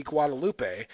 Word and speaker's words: Guadalupe: I slightly prayed to the La Virgen de Guadalupe for protection.